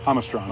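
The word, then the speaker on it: Jamastrán: How do you say that one? Yamastra or Hamastra? – Hamastron.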